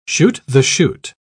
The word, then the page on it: chute-the-chute: British English